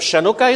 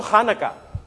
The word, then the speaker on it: Hanukkah: And it is in that story that we celebrate, that we find the great festivity of Chanukah, is it? No, Hanukkah. Hanukkah. Can we all say it? Hanukkah. Doesn't matter how you see it spelled, pronounce it Hanukkah.